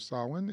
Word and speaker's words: Samhain: From sunset on October 31st to sunset on November 1st is the traditional celebration of Samhain, which is spelled S-A-M-H-A-I-N and looks like Samhain, but is pronounced Samhain.